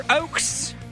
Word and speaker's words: Ochs: Newcomer tonight, Taylor Oaks. Last name is spelled O-C-H-S, pronounced oak like the tree, Oaks.